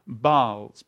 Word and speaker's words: Baals: And I'm going to continue to pronounce that Baals, B-A-A-L-S, okay? A group of gods.